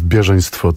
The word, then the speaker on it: Bieżeństwo: Tak, spektakl, który jest wynikiem naszej współpracy, zatytułowany jest Bieżeństwo 1915 i to akcentowanie na pierwszą sylabę nie jest przypadkowe, ponieważ jest to słowo z pochodzenia rosyjskiego,